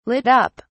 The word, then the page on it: lit up: British English